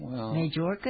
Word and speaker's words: Mayorkas: I think it's Mayorkas, but I'm not sure. – Well, there's no J in it. It's a Y. Mayorkas? Okay. – Yeah, well, he's jerking us around. Mayorkas' limited ability should understand that if you provide catch and release to the world's illegal aliens, then the whole world will come.